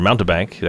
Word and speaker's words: Mountebank: So Mountbank, in case anyone is curious, or Mountebank, actually you pronounced the E there a little bit, is a person who deceives others, especially in order to trick them out of their money.